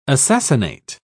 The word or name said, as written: assassinate